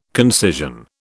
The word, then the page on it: concision: British English